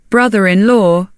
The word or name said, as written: brother-in-law